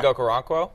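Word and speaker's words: Chig Okonkwo: I could see, and I'm gonna butcher his name. Okonkwo? – Oh, Chig? – Yeah. – Chig Okoronkwo? – Yeah, thank you.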